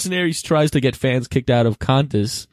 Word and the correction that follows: Qantas: Am I saying it right? – Contest? – It's Q-U-A-N-T-A-S. Qantas.